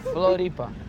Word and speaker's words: Floripa: Floripa. Is there a D in it? – Floripa. – That's it. End of it. We in Floripa. – We're in Floripa. – No, Floripa.